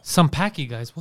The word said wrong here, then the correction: Sanpaku: what? – No, Sanpaku.